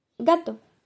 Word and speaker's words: gato: Il ne s'écrit pas de la même manière, mais il se prononce exactement de la, même manière que gâteau c'est-à-dire en espagnol gato.